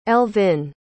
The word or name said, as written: elvyn